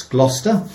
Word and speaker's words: Gloucester: I've had to teach Barry how to pronounce Gloucester because you pronounce it in a different way to the way you look at it.